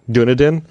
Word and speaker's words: Dunedin: the town he's in New Zealand is, I'm going to butcher it, Dunedin.